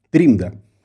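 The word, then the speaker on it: trimda: Я бы еще добавил, что там не только приезжие из рубежа, э, в Латвии они называются тримда. Вот такое есть определение для зарубежных латышей. Как? Тримда. Тримда. Да, вообще латышский язык очень интересен, там все ударения на первый слог том числе и на фамилиях, на именах.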